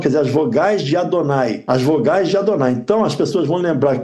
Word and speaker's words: Adonai: Cada vez que você vê elas escritas, você pronuncia Adonai. Você lê Yud Hei Vav Hei, pronuncia Adonai, que não tem nada a ver com essas letras.